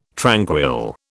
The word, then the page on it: tranquil: British English